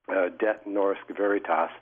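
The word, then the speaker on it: Det Norske Veritas: A second company with a name that's no easier to pronounce here, it's DET, like the abbreviation for Detroit. DET Norsk Veritas, and they're going to do a financial risk assessment looking at the cost of the worst-case scenario.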